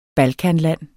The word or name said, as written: balkanland